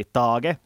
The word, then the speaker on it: Tage: Så här är ganska väntade namn, sådana namn som vi också har diskuterat på det som Mattias Macelli, Tage, som det ska uttalas nu har vi slagit fast den Tage Thompson, som för att citera William här, vilket monster, och det är ju helt sant med honom.